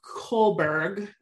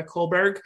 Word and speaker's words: Kullberg: Kohlberg. I think I'm saying that right, but it's like Erica with a K. N, and then her last name is K-U-L-L-B-E-R-G.